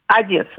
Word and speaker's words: Одесса: Или Одессу, как правильно говорить? – Одесса, да, угу.